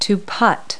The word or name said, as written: putt